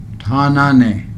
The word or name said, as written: taanaa nee